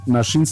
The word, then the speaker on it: naxin: Nashin se escribe Naxin y es el mazateco que significa venadito.